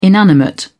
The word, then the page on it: inanimate: British English